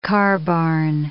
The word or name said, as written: carbarn